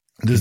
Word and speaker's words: DAZN: whether it's beIN Sport or DAZN, DAZN not DAZN, but DAZN. DAZN. – Confusing, confusing branding there, but DAZN is the pronunciation apparently.